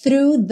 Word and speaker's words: through: Crash through the surface. Crash through. Through. Fafafá, linguinha lá no dente da frente. E já puxa no R. Through. Through. O GH não é pronunciado.